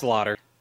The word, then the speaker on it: Sloter: It's Slaughter. – It's Slaughter, which sounds badass, right? But until you see that it's spelled S-L-O-T-E-R, and then it's just like, oh, that's unfortunate.